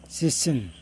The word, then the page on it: sisin: Yokoim